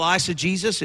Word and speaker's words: eisegesis: So how do you spell eisegesis? It's spelled E-I-S-E-G-E-S-I-S.